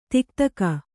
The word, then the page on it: ಥಕಥಾಕು: thaka thaku